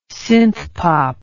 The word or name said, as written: synthpop